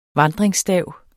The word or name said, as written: vandringsstav